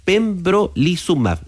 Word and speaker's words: pembrolizumab: pembrolizumab.